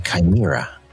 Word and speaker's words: chimera: What about a chimera? I think that's the term, right? When you take two different species and you create a new creature, a chimera.